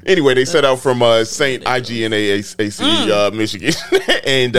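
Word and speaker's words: St. Ignace: Is it Ignacy or Ignacy? It's Saint Ignace.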